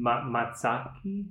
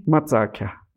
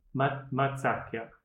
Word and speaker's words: Matsakya: So there's the Ashiwi style, and then the Matsaki. – Matsakya. – Matsakya. – Yeah, a lot of the archaeologists, they call it matsaki. – Okay. – Because of the pottery that's found there, they label it that way. And then it's pronounceable for them to say it instead of matsake.